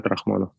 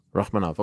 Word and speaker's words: Rakhmonov: Is it Shavkat Rakhmonov? Is that the right way to pronounce it? – Yeah, Shavkat Rakhmonov. – Rakhmonov, okay.